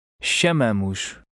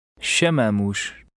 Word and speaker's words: chamamos: Remember the stress is in the middle of the word. – Chamamos. Chamamos.